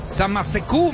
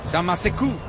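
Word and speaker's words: Samassékou: Sale con Samaseku. Samaseku, me dice Delgadillo que así se pronuncia. Samaseku.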